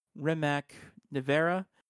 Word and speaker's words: Rimac Nevera: The first one will be the 2021 Rimac Nevera. Did I say that? Yeah, no, I said that correctly.